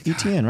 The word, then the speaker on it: Etienne: I mean, because the way Travis Etienne or whatever his name is, mm-hmm. Randall. – You said it right. Good job. – Is that right? – Yeah, Etienne, right? – It doesn't look right.